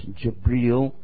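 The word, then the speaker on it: Jibril (جبريل): Same angel that we read about before, only it's pronounced Jibril in the Arabic language, came to him and told him directly an order, Iqra, which means recite.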